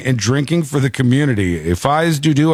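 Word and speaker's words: fais do-do: That's how they say it. The locals say Fize Dudu.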